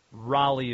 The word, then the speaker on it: Raleigh: It's not Raleigh, it's Raleigh. Raleigh is the proper pronunciation.